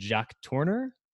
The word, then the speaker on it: Jacques Tourneur: It was actually directed by, you might have to help me with this pronunciation, Jacques Tourneur.